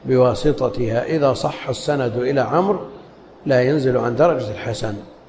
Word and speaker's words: عمرو: قال وعن عمرو بن شعيب، بعض طلاب العلم ينطقون عمرو بالواو والضم ومدري ايش، هذا الكلام ما هو صحيح هنا. وعن عمرو بن، والواو هذه يؤتى بها للتفريق بين عمرو وعمر، وإلا تأتي عليه الحركات كلها. اسمعوا، يتحدث عن شخص عمرو، ما هو صحيح، ما هو عمرو وعمر. رأيت عمرا، مررت بعمر، فالواو إنما لا تنطق، إنما يؤتى بها للتفريق بينه وبين عمر.